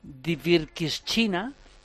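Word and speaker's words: Dvirkivshchyna: Andriy Shevchenko, que nació en una ciudad de nombre casi impronunciable, que es Dvirkishchina. – Ah, pues lo has dicho muy bien. – Lo he dicho bien.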